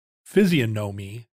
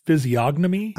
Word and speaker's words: physiognomy: Physiognomy? – I think the G might be silent. Like I might say physiognomy.